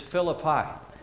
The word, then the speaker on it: Philippi: It's a college town that is pronounced incorrectly, all right? Just laying that out there. They pronounce it Philippi, and I'm like, it's Philippi, all right? Just setting that straight.